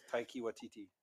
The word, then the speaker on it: Taika Waititi: It's Taika Waititi.